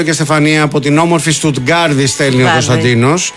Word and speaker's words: Στουτγκάρδη: Ναι, πέστηκε με προφορά, ρε παιδί μου. – Ναι, είναι Στουτγκάρδη.